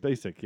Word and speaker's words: Spacek: I was going to interrupt you before, with something you were saying about I can't say her name. Say her name for me. Spacek. – Spacek, yeah.